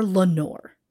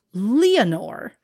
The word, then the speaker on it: Leonore: While some simply call her Lenore, I'm going to pronounce it Leonore, as it has been said in the movie Hollywoodland, and by Lois Lane herself, Phyllis Coates.